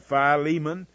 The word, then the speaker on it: Philemon: Now, if you want to call it Philemon, then you can do so. It's fine with me. I won't argue with you about it. You'll note that Unger's Bible Dictionary, though, has it pronounced the way that I'm pronouncing it. In case you want an authority, I just gave you a good one. These English Bibles we have, coming from England, have a lot more stress on some of the words than we normally put the way we say them here in the South.